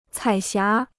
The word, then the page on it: 彩霞: cǎi xiá